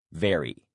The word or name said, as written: vary